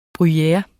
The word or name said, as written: bruyere